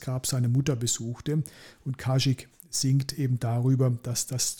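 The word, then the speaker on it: Kazik: Der stammt von der Band Kasik, wenn ich es richtig ausgesprochen habe. Habe ich nicht, von Kasik.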